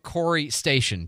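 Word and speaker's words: Corry Station: You say CIWT, but it's spelled C-I-W-T, the Center for Information Warfare Training, stationed on board NAS Pensacola Corey Station. Did I say it right? – You did.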